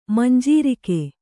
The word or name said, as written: ಮಂಜರಿಗೆ